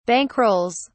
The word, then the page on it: bankrolls: British English